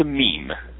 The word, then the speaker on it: meme: Let me ask you about, there's a term called Mimi. How do you pronounce it? – It's a meme.